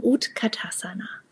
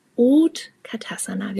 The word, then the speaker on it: Utkatasana: Denn der Stuhl bedeutet in Sanskrit ausgesprochen Utkatasana. Utkatasana.